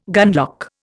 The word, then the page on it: gunlock: Indian